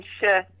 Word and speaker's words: Shu: Allen Shu you say that's how to pronounce his last name Allen Shu